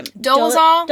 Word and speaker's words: Dolezal: Dolezal.